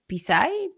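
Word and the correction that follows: Psy: P-S... – Sai, Sai. – Sai, ya.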